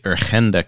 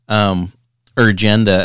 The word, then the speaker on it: Urgenda: And I guess of all the cases in the world, probably the one that more people know more about is the famous, I'll pronounce it in Dutch, Urgenda case in Holland. Urgenda, as you would say in English, I guess. Urgent agenda, the combination of those two words,